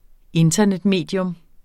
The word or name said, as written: INTERNETMEDIUM